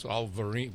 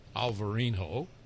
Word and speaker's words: Alvarinho: And there it's spelled with an H, so it's Alvarinho, with a V and an H, Alvarinho.